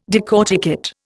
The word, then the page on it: decorticate: Indian